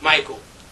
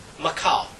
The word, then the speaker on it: Michal: It seems as if the name should be pronounced Michael. But by virtue of the fact that we cannot regender the phonics, we have a certain amount of literary license. And I believe her name should be pronounced Michal.